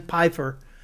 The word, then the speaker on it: Pfeiffer: He actually talked about Dan Pfeiffer. Sorry, the P is silent. – Yeah, like Fisher Fawcett.